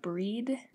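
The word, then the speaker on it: Bríd: which also includes the Modern Irish spelling B R I D, which is pronounced breed.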